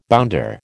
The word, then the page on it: bounder: American English